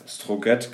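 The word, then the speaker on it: Strøget: Und ähm, wie gesagt, also in, in, in Kopenhagen gibt es die längste Fußgängerstraße der Welt, die heißt Stroget. Ich weiß nicht, ob ich es richtig ausgesprochen habe. Den würden mich wahrscheinlich jetzt schlagen. Aber. Strogat. Strogat, genau.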